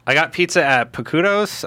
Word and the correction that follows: Pequod's: I don't know how to say it. Pequod's. P-E-Q-U-O-D-S. P-E-Q-U-O-D-S. – Okay.